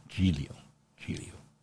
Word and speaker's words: Giglio: Giglio in Italian is pronounced with a soft G. Giglio.